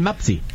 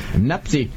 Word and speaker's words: Mpasi: No va a sonar muy conocido porque la pronunciación es Lionel Mapsi. – Qué capricho. Así parece un capricho del destino. – Nazú. – ¿Cómo era apellido? – Mapsi. – Es M-P-A-S-I.